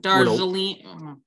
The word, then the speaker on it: Darjeeling: It's Jarlene. I can never say it right. – Jarlene. – You're not supposed to pronounce the D, I don't think. – Darjeeling.